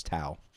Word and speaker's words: Tao: The band is Tao and the Get Down, Stay Down. The album is Temple. The song is Phenom. – I think it's pronounced Dow. – It's Tao. Her name is Tao Nguyen, so.